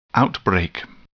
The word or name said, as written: outbreak